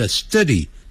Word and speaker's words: study: I plan to study abroad，我计划要念书，study 变念成 study，叫 study，肚子饿的饿，study abroad 就在海外，就是我计划要留学，留学就叫就。